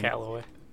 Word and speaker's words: Callaway: Callaway.